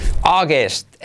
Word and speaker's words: August: August, también la pronunciación complicada, en este caso por, ahí está la vocal, esta primera, que es como saw.